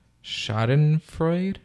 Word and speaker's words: Schadenfreude: Schadenfreude? – Yeah, that's it. – That's it. Did there, I say it right? – Yeah, Schadenfreude, I think.